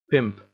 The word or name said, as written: pimp